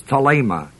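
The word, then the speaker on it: thelēma: And the word will is thelema, T-H-E-L-E-M-A. Long E, by the desire.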